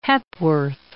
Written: Hepworth

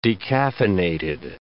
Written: decaffeinated

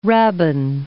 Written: rabbin

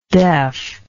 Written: deafness

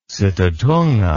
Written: sitatunga

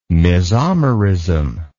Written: mesomerism

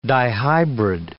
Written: dihybrid

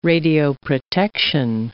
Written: radioprotection

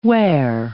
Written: ware

